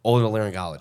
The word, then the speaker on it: otolaryngology: Otolaryngology. – Otolaryngology. Yeah. – Okay. – Ear, nose, and throat. – Yes, that's correct. – ENT. – If you prefer seven-syllable words, it's otolaryngology.